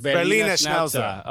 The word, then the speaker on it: Berliner Schnauzer: Berlin Schnauzer? – Berliner Schnauzer. – Berliner Schnauzer.